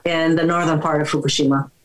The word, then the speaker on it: Fukushima: Do you pronounce it Fukushima or Fukushima? – No, Fukushima. – Fukushima. Okay.